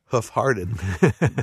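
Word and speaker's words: Hoof Hearted: But the other interesting thing is, so much of this, even Rich's favorite, Hoofhearted, they're all food. There's food aspects. – Well pronounced.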